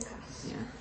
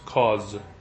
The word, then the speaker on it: Cos: And I watched a Hollywood movie in which that name was being pronounced Cause or something like that. Cos is probably just what my English-speaking tongue is doing to that Spanish name.